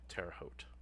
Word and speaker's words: Terre Haute: Is that how you pronounce it? I'm glad someone told me how to pronounce that. But yes, in Terre Haute.